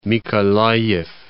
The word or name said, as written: Mykolayiv